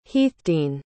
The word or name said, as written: heathdene